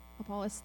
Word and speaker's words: Apollos: Apollos, thank you.